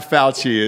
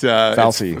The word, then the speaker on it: Fauci: I like how you give it a soft C. It's not Fauci. It's Fauci.